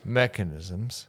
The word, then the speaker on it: mechanisms: What did I say? – Mechanicisms. – That's the right way to say That's not a word. – Mechanicisms. – Mechanisms.